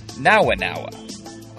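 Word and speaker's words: Nowa Nowa: I mean, another big one, N-O-W-A, and then the second word is N-O-W-A. Nawa-nawa. Oh, no, that's now-now, you know?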